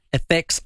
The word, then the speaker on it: Efex: The best thing you can do right now is get in touch with a trusted cybersecurity company called FX IT. Yep, it is spelled E-F-E-X.